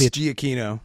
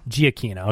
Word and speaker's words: Giacchino: How do you say it's Giacchino? – Giacchino.